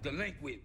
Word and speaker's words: DLEQUINT: It's pronounced delinquent, bitch.